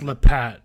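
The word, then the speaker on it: Lappat: Roman, and I'm not sure how to pronounce it, but it's spelled Roman Lappet dot miniature. – It's Lappet. It's Lappet. I've heard him.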